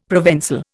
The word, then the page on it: ProvenÇal: Indian